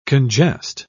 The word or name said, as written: congest